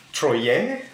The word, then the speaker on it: troller: French, so troller, T-R-O-L-L-E-R. How would you pronounce this in French? – Troller. – Troller. – Troller. – Troyer? – Troller. – Are you sure it's not a Y sound? – No, I'm not sure.